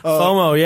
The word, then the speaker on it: FOMO: And I was wondering, because you mentioned FOMA yesterday. Was that FOMA. – How you say it? – FOMO, yeah.